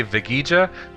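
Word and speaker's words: Veguilla: I'm on Facebook at Jorge Vigija. That's spelled J-O-R-G-E. Last name spelled V as in Victor. E-G-U-I-L-L-A.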